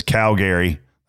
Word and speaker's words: Calgary: Calgary. That's how you say it, Canada.